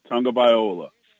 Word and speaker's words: Tagovailoa: And if Tua Tagovailoa, for my, however you say his name. – Tua Tagovailoa. – It's not tongue. It's tag.